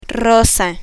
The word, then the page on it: rosa: Spanish